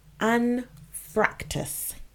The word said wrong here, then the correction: anfractuous: Oh, I said that wrong. Anfractuous.